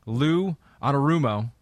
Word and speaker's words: Lou Anarumo: Lou Anarumo is doing an amazing job with this unit.